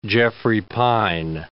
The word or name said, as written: Jeffrey pine